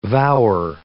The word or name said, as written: vower